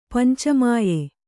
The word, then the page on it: ಪಂಚಮಾಯೆ: Kannada